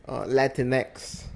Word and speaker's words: Latinx: Latinx.